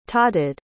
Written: totted

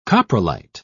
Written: coprolite

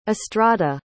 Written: estrada

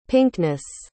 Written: pinkness

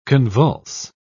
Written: convulse

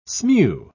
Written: smew

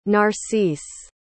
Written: narcisse

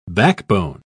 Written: backbone

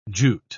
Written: jute

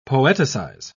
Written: poeticize

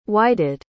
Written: whited

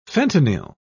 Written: fentanyl

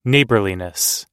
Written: neighborliness

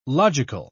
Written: logical

